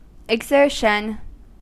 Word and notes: exertion: American English

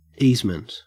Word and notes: easement: Australian English

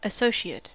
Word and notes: associate: American English

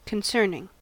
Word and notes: concerning: American English

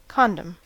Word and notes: condom: American English

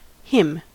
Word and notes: hymn: American English

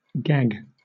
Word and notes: gag: British English

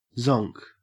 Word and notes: zonk: Australian English

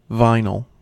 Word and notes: vinyl: American English

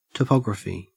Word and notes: topography: Australian English

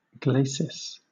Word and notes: glacis: British English

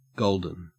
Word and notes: golden: Australian English

